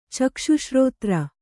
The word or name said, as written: ಚಕ್ಷುಶ್ರೋತ್ರ